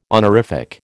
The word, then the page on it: honorific: American English